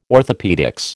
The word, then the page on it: orthopaedics: American English